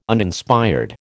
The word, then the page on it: Uninspired: American English